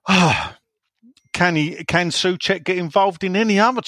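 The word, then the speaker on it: Souček: Uh, do you think we can get uh, Kuchek in? Sorry, Soucek.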